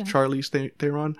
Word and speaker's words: Charlize Theron: It stars Charlize Theron. Am I saying her name right? – Yeah. – Charlize Theron.